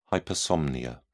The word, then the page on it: hypersomnia: British English